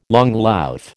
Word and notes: langlauf: American English

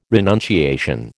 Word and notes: renunciation: American English

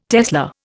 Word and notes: tesla: Indian

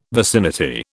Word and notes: vicinity: British English

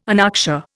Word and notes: anoxia: Indian

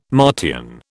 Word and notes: Martian: British English

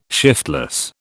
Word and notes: shiftless: British English

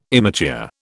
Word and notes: immature: British English

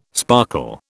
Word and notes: sparkle: British English

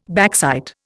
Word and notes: backsight: Indian

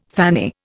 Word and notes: fanny: Indian

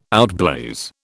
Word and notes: outblaze: British English

